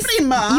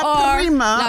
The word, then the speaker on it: La Prima: Now, do I say it like Italian? – Yes, La Prima. You got to roll your R. – La Prima.